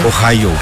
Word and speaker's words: Ohayo: Ohayo. – Ahí está. Preguntale porque ayer ensayó. – Ohayo. – Ohayo.